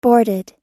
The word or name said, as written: boarded